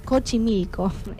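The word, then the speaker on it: Xochimilco: Eh, es imperdible si visitas los canales de Chocomilco, no, perdón, Cochimilco, es muy complicado pronunciar, y se encuentra dentro del conteo de los lugares más embrujados del mundo.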